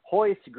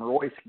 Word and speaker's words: Royce: I was not aware that in Brazil, your R's are pronounced like H's. So everybody probably calls him Royce Gracie. But when you get down into the technicalities of how the Brazilians do things, it is pronounced Hoist Gracie.